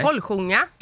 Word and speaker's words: Holsljunga: Holsljunga camping, uttalar man det så? – Nej. Holsljunga.